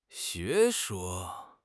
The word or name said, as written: 学说